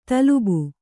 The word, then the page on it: ತಲುಬು: talubu